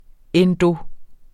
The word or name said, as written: endo-